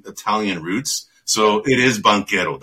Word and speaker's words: Banchero: I got to tell you, man, the last time I was talking to William about this a few podcasts ago, dude, I had no idea that's how you pronounce it. I was always wondering when I heard it in college, by the guys who call the games in college when they were calling the Duke games, they're like Paolo Banchero. I'm like, oh my God, these guys, man, they're just butchering them. It's Banchero, man, right?